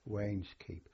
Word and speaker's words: Wainskeep: Wayne's Keep. – Now you spell it? – W-A-I-N-S-K-E-E-P.